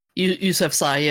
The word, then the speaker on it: Yousafzai: Yousafzai, yeah. That's how they pronounce it, yep.